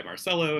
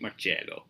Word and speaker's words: Marcello: But I have absolutely no Italian heritage whatsoever, and I'm pretty purely American, so I just go by Marcello, and that's my actual name as normally pronounced, but I have no problem with people pronouncing it Marcello, because that is correct, and that's how it would be in the traditional Italian, so I take it both ways.